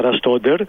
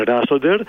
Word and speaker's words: Ραστόντερ: Αυτή είναι η σωστή προφορά, Ραστόντερ, γιατί έχουμε και την ενημέρωση από την ΠΑΕ.